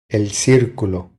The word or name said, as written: El circulo